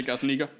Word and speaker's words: Gazzaniga: The young lad, or I'm not sure how young he is anymore, but Paolo Gazzaniga, is that how you say it? It could be very poorly pronounced. – Yeah, Gazzaniga.